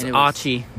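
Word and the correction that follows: açaí: Or Aussie. My bad. Aussie. – That's Aussie. – How it's pronounced. – Aussie. Mhm. – A C A I.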